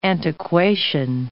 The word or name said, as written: antiquation